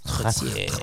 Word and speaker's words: Trottier: You must roll your R's. Trottier.